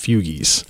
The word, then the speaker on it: Fugees: The band is pronounced fugies.